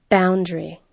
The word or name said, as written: boundary